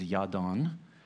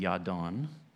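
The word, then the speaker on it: Jadon: Your name, it's the Hebrew is Yadon, and that's where the Celtic or English name that we call Jaden came from. So it's J-A-D-O-N in Nehemiah, but their pronunciation would be Yadon.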